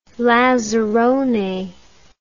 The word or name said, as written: lazzarone